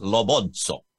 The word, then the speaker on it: Lobozzo: It is 100% Italian. It's pronounced Lobozzo.